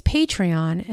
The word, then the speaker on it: Patreon: So I thought this would be a great opportunity to explain it, because Patreon, and I'll spell it, because it's almost like it's not so intuitive, right? So P-A-T-R-E-O-N, Patreon. So kind of patron.